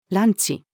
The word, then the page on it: ランチ: female